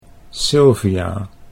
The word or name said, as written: Sylvia